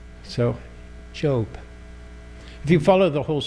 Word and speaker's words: Job: It's always fun with catechism because they've never seen the word, never spoken, so first time they pronounce it, it's job. And you chuckle and say, no, it's Job. It's Job.